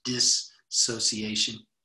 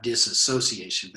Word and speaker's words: dissociation: And I like this term because it seems to be helpful uh, separation and dis, uh, dissociation. I think is how that's pronounced dissociation, not disassociation, but dissociation.